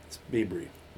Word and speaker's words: Biebricher: B-I-E-B-R-I-C-H-E-R. – Bieber. I always mispronounce it because I try and say it phonetically. It's right, right. – Bibry. – It's Bibry. Bibry.